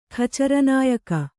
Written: ಖಚರನಾಯಕ

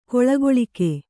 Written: ಗೊಳಗೊಳಿಕೆ

